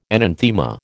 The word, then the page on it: enanthema: American English